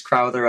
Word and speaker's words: Crowther: It is indeed pronounced Crowther.